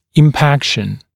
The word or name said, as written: impaction